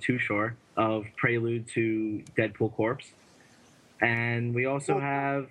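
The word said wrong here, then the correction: Corps: It's pronounced Core. Core. It's not a dead body. – I messed that up. I'm sorry. We actually had a debate about that in intern role the other day, how to pronounce it. And, of course, it is Core, like the Marine.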